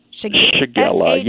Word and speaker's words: Shigella: Shigella? Am I saying it right? – Shigella, yes. – S-H-I-G-E-L-L-A?